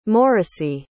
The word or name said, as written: morrisey